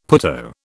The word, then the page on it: putto: British English